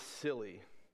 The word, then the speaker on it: Scilly: Unless you pronounce the C hard, it would be Scilly. But I believe it's Scilly.